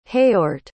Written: heort

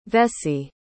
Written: vessie

